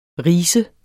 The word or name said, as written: rise